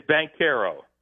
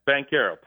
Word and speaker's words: Banchero: It's Banchero. Just bank, like you're going to the bank. Banchero. Paolo Banchero. – Paolo Bank. – No ch in there at all. There's just Paolo Banchero.